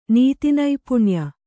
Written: ನೀತಿನೈಪುಣ್ಯ

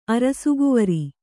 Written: ಅರಸುಕುವರಿ

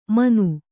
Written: ಮನು

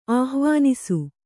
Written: ಆಹ್ವಾನಿಸು

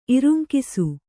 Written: ಇರುಂಕಿಸು

